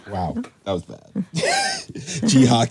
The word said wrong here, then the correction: Jin-hee: Kim. – Jin-hee Kim. That's Jin-hee.